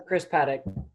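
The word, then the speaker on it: Paddack: Let's go with the Padres, Chris Paddock. Is that correct pronunciation? – Yeah, Chris Paddock.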